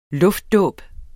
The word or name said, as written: luftdåb